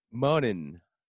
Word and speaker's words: Monnin: Monnin. – Monnin? M-O-N-N-I-N, yeah.